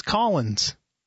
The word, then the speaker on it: Collings: Even though it says Collings? – No, but that's with an A. It's Collins.